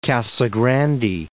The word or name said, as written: Casa Grande